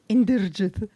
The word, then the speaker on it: Inderjith: So I'm speaking to Denise Inderjith. Am I saying it right? – Mm-hmm. – Inderjith.